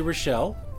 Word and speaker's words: Rachel: I always say Rachel, and it is correct, but it's spelled R-A-C-H-E-L.